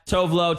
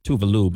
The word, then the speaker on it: Tove Lo: Then you got Tove Lo. Technically, she goes by Tuvalu, but no one pronounces it right.